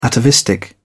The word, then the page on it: atavistic: British English